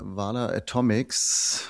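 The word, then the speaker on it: Valar Atomics: Und zwar haben wir das US-Unternehmen Vala Atomics. Habe ich das richtig ausgesprochen? – Klingt richtig.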